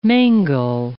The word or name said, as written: mangel